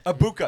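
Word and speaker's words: Egbuka: Abuka, because he's got that weird G that in there. – He's tied Abuka.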